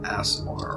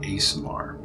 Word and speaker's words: Aasimar: Uh, beyond this door lies the tomb of Omor, a fallen Asmar or Asmar, which is spelled A A S I M A R.